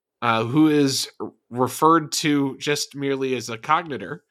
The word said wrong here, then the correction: cogenitor: as a cogenitor.